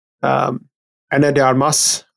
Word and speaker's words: Ana de Armas: You know, Ryan Gosling, Chris Evans, Ana de Armas, I think that's how you say her name. – that's how I say it, yeah.